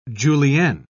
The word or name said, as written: julienne